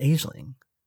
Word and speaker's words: Aisling: I think right she now. Pronounces it Aisling. – Aisling? – Aisling.